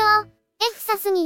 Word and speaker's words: FSAS: FCNP というのもその略称でしたし、有名なところで言うと、富士通エフサスのエフサスというのは、旧社名の富士通サポート& サービスの略称、エフサスに由来するそうです。ちなみに現在の略称も FSAS と書いてエフサスですね。